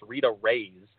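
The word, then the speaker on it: Reis: And then the other one that was specifically asked about was, it does look like Rita Reese, and I was saying it like that for a while until I finally heard it pronounced, and it's Rita Rays, like a ray from the sun, I guess.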